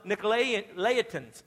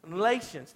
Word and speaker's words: Nicolaitans: Likewise, you also have those who hold to the teaching of the Nicolaitans. I haven't said that right yet. Nicolaitans, thank you, the Nicolaitans.